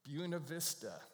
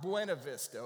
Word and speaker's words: Buena Vista: Not Buena Vista. I'm from California for years. It's Buena Vista out there. It's Buena Vista here. They'll think you're from somewhere else if you don't pronounce that right.